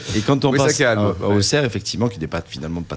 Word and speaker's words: Auxerre: Ça s'écrit Auxerre et ça se prononce Auxerre. – Auxerre. – Et les Bourguignons ont toujours eu un problème avec les X.